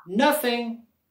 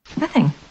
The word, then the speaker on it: nothing: En la palabra nothing, queremos subir la voz y la entonación en la primera sílaba. Nothing. Nothing. Y aquí tenemos el otro sonido TH que es el TH mudo.